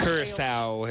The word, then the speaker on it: Curaçao: Caracho? It's got the little... – Curaçao.